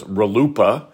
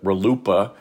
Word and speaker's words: RLUIPA: This is universally pronounced as RLUIPA, uh, and uh, it, it, sounds like a, a joke, but RLUIPA.